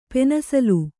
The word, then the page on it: ಪೆನ್ಸಿಲು: pensilu